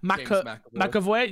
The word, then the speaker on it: James McAvoy: James McAvoy.